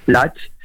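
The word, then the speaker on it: Latche: qu'on dit l'Atché. Enfin, il ne faut même pas prononcer le E, a priori, l'Atché.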